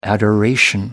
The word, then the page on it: adoration: American English